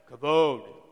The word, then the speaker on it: kavod: It's supposed to be weighty or heavy, so you're supposed to say it like kavod.